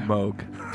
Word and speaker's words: Moog: Moog.